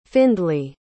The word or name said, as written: findlay